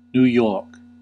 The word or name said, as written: New York